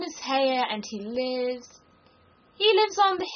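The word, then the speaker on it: Haigha: His name is Haya." – He pronounced it so as to rhyme with mare.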